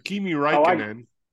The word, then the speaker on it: Kimi Räikkönen: Kimi Räikkönen.